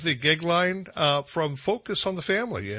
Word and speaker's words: Goeglein: It's Timothy, and his last name's Gigline, and it's spelled GOEGLEIN.